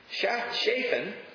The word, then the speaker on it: Shaphan: And Hilkiah gave the book to Zaphan, who read it. I'm sorry, I'm saying that wrong. Shaphan, who read it.